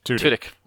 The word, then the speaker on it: Tudyk: Which is supposed to be by Alan, was it Turkins? – Tudyk. – Sorry, I in my head I can see the word, but I'm saying it wrong.